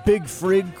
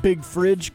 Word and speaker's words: Big Frig: Do you think it's definitely Big Frig cooler and not Big Fridge cooler? – It's spelled on the card, and I can only read what's on the card. – It's on the card. – On the card, and it's spelled F-R-I-G. – So that's, if it was fridge, it'd have a D in there.